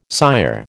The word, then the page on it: sire: American English